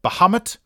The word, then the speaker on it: Bahamut: It's pronounced Bahamut or Bahamut. And yes, you can pronounce Bahamut into Google and you will get American and British pronunciation. Should learn how to pronounce pronunciation. Bahamut, American. Bahamut, British. Two options there.